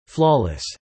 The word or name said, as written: flawless